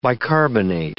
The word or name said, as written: bicarbonate